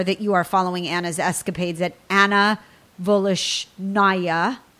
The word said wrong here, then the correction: Voloshyna: Vol, Vol, say it for me, Anna at Anna Voloshyna Cooks. Voloshyna, thank you. I'm going to spell it. It is V O L O S H Y N A, Anna Voloshyna Cooks.